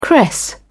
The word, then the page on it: cress: British English